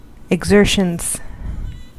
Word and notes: exertions: IPA : /əɡ.ˈzɝ.ʃənz/; American English

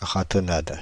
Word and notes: ratonnade: /ʁa.tɔ.nat/

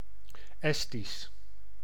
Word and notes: estonien: France; [ɛs.tɔ.njɛ̃]

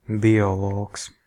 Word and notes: biologiste: France; [bjɔ.lɔ.ʒist]

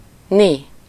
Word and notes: ni: /ˈni/

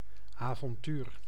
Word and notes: avontuur: /aːvɔnˈtyːr/

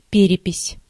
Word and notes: перепись: /ˈpʲerʲɪpʲɪsʲ/

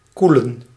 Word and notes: refroidir: France; [ʁǝf.ʁwa.diʁ]; /ʁə.fʁwa.diʁ/

